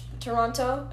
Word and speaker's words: Toronto: You know how you're supposed to pronounce Toronto? It's Toronto. – Toronto, yeah, you don't say the second T.